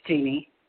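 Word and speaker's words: Jeanne: My first name is Jeannie. – Hi, how are you doing? Is it Jeannie or Jean? – It's Jeannie. – Jeannie. How do you spell it? – J-E-A-N-E. – J-E-A-N-E? – N-N-E. – N-N-E.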